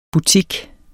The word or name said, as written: boutique